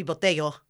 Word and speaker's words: Botello: It's supposed to be Botello, but my dad wanted to anglicize it for the Americans, so he goes by Botello, but everybody in Caracas, my whole family, likes Botello. That's how they all say my name.